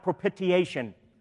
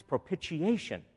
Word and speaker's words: propitiation: Yes, we are going to learn to say the word propitiation this morning. And I'll tell you now, it's not propitiation. That's not how you... It's propitiation. Got to say it right if you're going to be good theologians.